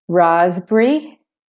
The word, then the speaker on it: raspberry: Raspberry. So the P is silent in the middle there. Raspberry, and the S is saying Z.